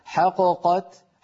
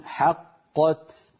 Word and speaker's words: حقت: لو قلقلت الأولى لم فك الإدغام، يعني لو قلنا حققت حققت فانفك الإدغام وصار كأننا نطقنا بقافين منفكتين، وهذا لا يصح أبدا، لابد أن نقول حقت.